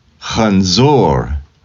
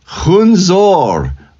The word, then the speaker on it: Chanzor: What I didn't know was that I had mispronounced the key word at the end, which is Chanzor, but I said Chunzor over and over and over again.